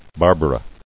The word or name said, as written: Barbara